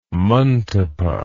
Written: mantapa

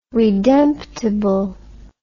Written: redemptible

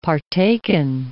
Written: partaken

